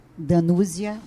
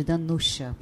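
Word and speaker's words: Danuza: E Lacerda era engraçado, se escreve, se escreve Danúzia Bárbara. Mas quer mostrar que não tinha intimidade e não conhecia o mundo dela, era chamar de Danúzia. Danúzia. Porque ela, todo mundo chamava de Danusha, porque era é de família polonesa.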